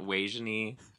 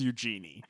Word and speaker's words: Eugenie: It's spelled, it's spelled E-U-G-E-N-I-E. So it looks like Eugenie. I randomly went to high school with a girl who has that name, and it was pronounced Weijenie.